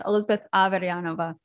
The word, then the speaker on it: Elizabeth Averyanova: And it's Elizabeth, and even it's very difficult to say, but Elizabeth Averyanova.